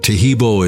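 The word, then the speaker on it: Taheebo: Tahibo is spelled T-A-H-E-E-B, like boy, O, then the word T.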